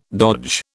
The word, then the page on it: dodge: British English